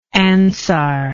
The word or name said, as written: ansar